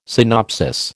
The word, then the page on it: synopsis: American English